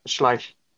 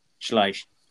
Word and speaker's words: Schleich: I'm pretty sure it's just Schleich. – Schleich.